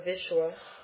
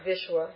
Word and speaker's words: Vishwa: Vishwa, that's a short V, V, and then Shwa, that's a Sh plus a V, so Vishwa, right? Vishwa. – Mm-hmm. Because of that Sh in front of it, it's a V. – Oh, yes. When the letter V, or actually the perfect pronunciation is W, but V and W, V changes to W in classical Sanskrit. It'll be V when it's surrounded by a consonant or in conjunct consonant with a semi-vowel.